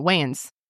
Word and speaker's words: Wayans: No, Wayans. Is it pronounced Wayans? – Like the Wayne brothers? – No.